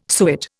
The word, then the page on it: suet: Indian